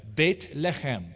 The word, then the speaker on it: Beit Lechem: Bet, house, lechem, bread. Beit Lechem is how it's pronounced in Hebrew.